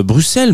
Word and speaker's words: Bruxelles: On va aller du côté de Bruxelles, euh, Bruxelles même, puisqu'on ne prononce pas le X, Bruxelles,